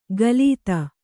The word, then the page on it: ಕಲಿತ: kalita